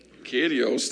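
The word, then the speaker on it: Kurios (κύριος): When he said Lord, the Greek word there is kurios. Kurios. There we go.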